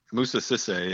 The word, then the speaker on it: Moussa Cissé: Okay, so now that you've burst our bubble on him, what about Moussa Cissé? Is that, am I pronouncing it correctly? Cissé? – Yeah, Moussa Cissé, yeah.